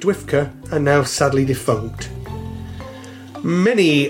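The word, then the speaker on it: DWFCA: The usage was more common among fans in the United States during the 1980s, when the Doctor Who Fan Club of America, pronounced by her members as Dwifka, now defunct sadly, published the Whovian Times as its newsletter.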